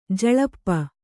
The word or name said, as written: ಜುಲ್ಪಿ